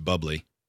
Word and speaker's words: Bublé: I think it's pronounced bubbly.